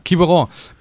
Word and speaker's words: Quiberon: Quiberon, Platz 4, 5 und 7 bei der 49er und 49er FX WM.